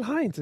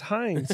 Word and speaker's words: Heinz: It's not Heinz. It's Heinz. – It's Heinz like Doofenshmirtz, man. Come on. – Get out of here. – That's how you say it. Heinz is just how they Americanized it, so they didn't.